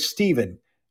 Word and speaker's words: Stephen: Remember that PH can also make the V sound. So like in the word Stephen, if we pronounce with a PH, in Phoenician, we have the V sound.